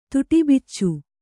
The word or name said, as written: ತುಟಿಬಿಚ್ಚು